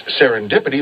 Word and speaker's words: serendipity: So, for example, it's serendipity that Reading and Reading are spelled the same. Or if you find something very nice, it's just serendipity stepping in. Serendipity, S-E-R-E-N-D-I-P-I-T-Y. That funny last three syllables, dippity.